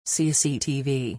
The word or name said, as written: CCTV